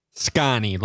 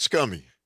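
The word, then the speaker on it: Sconnie: How do you pronounce that? – SCANI, like Wisconsin.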